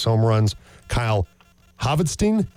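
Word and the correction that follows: Hvidsten: am I getting that right? – Vidston, H is silent. Vidston, thank you. – Like a Viking, yeah.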